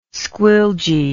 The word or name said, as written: squilgee